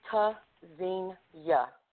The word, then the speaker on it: Tzynya: Pronounced ta-zin-ya, verb from Hebrew, meaning to listen for, hear. Spelled T-Z-Y-N-Y-A.